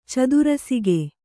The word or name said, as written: ಚದುರಸಿಗೆ